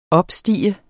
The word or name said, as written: opstige